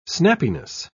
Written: snappiness

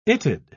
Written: it'd

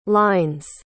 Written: lines

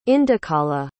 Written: indukala

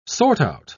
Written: sort out

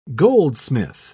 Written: goldsmith

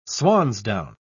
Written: swansdown

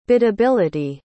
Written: biddability

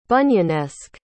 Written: Bunyanesque

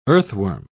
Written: earthworm

